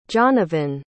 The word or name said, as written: johnavan